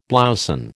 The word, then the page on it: blouson: American English